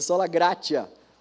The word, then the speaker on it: gratia: Deixa eu, deixa eu ver se eu falei certo isso aqui. Eu, é isso aí. É sola gratia. Eu falei gratia? Falei, né? Isso aí.